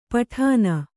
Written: ಪಠಾನ

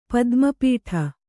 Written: ಪದ್ಮಪೀಠ

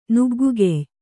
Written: ನುಗ್ಗುಗೆಯ್

